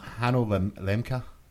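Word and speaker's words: Hanno Lemke: Earlier last month, general manager at Microsoft Studios Europe, Hanno Mika, I might have said that wrong, Mecca? Lemeca? – Hanno Lemeca.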